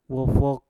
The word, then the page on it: wofok: mpur